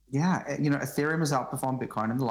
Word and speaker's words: Ethereum: Ethereum? – Yeah, Ethereum.